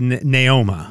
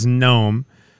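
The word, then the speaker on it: Neoma: N-E-O-M-A, a.k.a. Gnome, N-O-M-E. – Gnome. – Would you say Gnome? N-E-O-M-A. I would have said Naoma. – Naoma, yeah. – But I guess it's Gnome. Kevin, this person's name is iCup. Can you spell that one? – iCup. Like I-C-U-P? – Jay, Kevin, and Slim in the morning.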